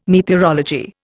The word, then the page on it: meteorology: Indian